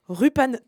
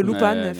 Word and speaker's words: Lupin: Vidoc 4 ou encore Rupan 3, qui est une ah prononciation japonaise de Lupan.